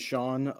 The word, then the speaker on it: Sean: Um, my name is Sean Owen Robertson.